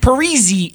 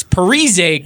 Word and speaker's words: Parise: Parisi isn't Parisi. It's Parise because he's Italian, and he changed his name when he went back to Minnesota to sound more, I don't know, like a caker.